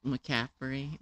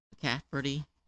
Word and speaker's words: McCaffrey: It's McCaffrey, not... Cafferty.